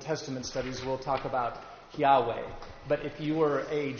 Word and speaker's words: Yahweh: So there's been in the old days, they used to pronounce it Jehovah, if you've ever heard that way of pronouncing it. Scholars today are pretty agreed that it's called, you would pronounce it Yahweh.